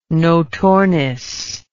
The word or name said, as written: notornis